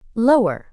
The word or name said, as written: lower